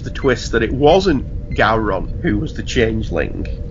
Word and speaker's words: Gowron: You have to say it like Gowron.